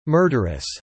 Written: murderous

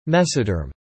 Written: mesoderm

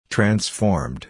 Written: transformed

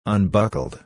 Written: unbuckled

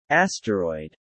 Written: Asteroid

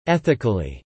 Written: ethically

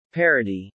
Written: Parity

